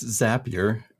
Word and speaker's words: Zapier: So first I would like to politely correct you on the pronunciation. It's it's Zapier, because Zapier makes me happier.